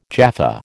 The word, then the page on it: Jaffa: American English